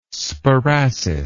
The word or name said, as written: sparassis